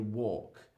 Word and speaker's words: walk: Do we say the L? We don't with walk. That is a silent letter. We say walk.